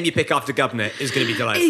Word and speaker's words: Gobnait: so now we just every day refer to the unborn child as Gubnit, which is spelled G-O-B-N-A-I-T and is a legitimate Irish name.